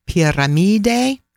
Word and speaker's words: Piramide: And it's called the pyramid in Italian is pronounced Piramide.